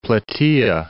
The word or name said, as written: Plataea